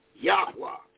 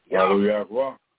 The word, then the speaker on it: Yahweh: And how you pronounce it, one of them big scholars, they did pronounce it in that 30-minute lecture they gave, Yahweh. And that is correct. – Yahweh.